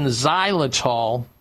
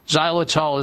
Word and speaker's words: xylitol: and mints are not rich in xylitol. Xylitol is spelled X-Y-L-I-T-O-L.